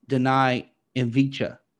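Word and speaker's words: Deni Avdija: His name is Denai Avdija.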